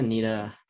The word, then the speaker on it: Ntilikina: And, you know, even like, you know, Frank Ntilikina, or how do you pronounce it? Ntilikina. Okay, yeah.